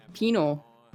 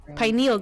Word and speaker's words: pineal: Pineal gland? Pineal. How do you say it again? – Penal. Right? – No. Pineal gland.